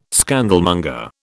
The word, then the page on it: scandalmonger: British English